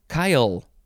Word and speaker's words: chyle: The Yiddish is spelled K-H-U-T-S-P-E, and the Lower Hebrew is H-U-S-P-A-H. Oh, it's such a good word, chutzpah. Next is Kyle, C-H-Y-L-E. It's not the name, Kyle.